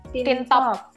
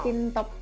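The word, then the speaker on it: Teen Top: Nah, yang pertama ada member Tintop, Top. Itu Niel. – Niel. Tin Top, Tin Top. – Iya, bacanya Tin pakai pakai spelling Koreanya Tin sih.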